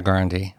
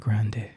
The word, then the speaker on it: Grande: Ariana Grande. – I think we were saying Grande, and it's really Grande. And she says Grande.